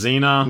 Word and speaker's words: Xena: Or people with an X where it's pronounced like a Z, I'll pass that too. – I won't. – Like if your name is Xena.